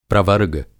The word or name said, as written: प्रवर्ग